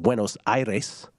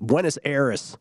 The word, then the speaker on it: Buenos Aires: It is in Buenos Aires, or as every American announcer just insists on pronouncing it, Buenos Aires.